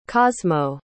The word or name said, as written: cosmo